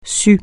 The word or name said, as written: sus